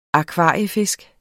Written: akvariefisk

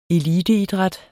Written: eliteidræt

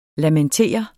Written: lamentere

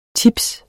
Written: tips

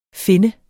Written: finne

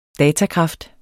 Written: datakraft

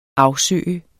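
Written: AFSØGE

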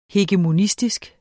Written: hegemonistisk

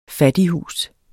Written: fattighus

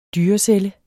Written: dyrecelle

